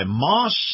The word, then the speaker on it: Maus: This portion of the program brought to you by Moss, that's spelled M-A-U-S, Acura.